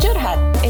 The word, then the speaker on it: curhat: It's pronounced curhat. It's an Indonesian term.